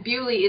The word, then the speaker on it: Beaulieu: And Beaulieu is actually how it's pronounced correctly today.